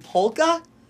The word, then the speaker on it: polka: So to actually hear the L in polka, that's... I'm making a concerted effort right now to pronounce it correctly.